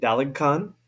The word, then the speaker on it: Daligcon: All right, so uh, today we have uh, Nate Daligcon.